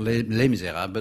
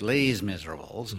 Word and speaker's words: Les Misérables: But they pronounced it not Les Misérables, but Les Miserables.